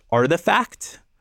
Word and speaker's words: RTFKT: RTFKT, which if you say that loud, is pronounced Artifact, which I did not know until recently.